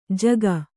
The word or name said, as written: ಝಗ